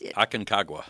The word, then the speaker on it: Aconcagua: So when I said, how do you say this? Aconcagua. And he said it just. – Aconcagua. – Yep. Aconcagua.